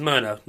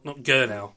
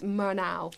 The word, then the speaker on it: Murnau: And this is Murnau, not Gurnau. – Some people find these German names quite difficult to pronounce, but it's Murnau.